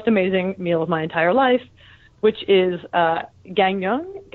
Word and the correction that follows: Gangneung: I think it's Gangneung. The G's are pronounced like K's, which threw me off when I was trying to.